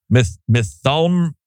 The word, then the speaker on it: Mytholm: Methumroid. – Is that how you say it? – Yeah. I looked it up. – M-Y-T-H-O-L-M. Methumroid? Oh, that sounds about right. – Yeah, that's it.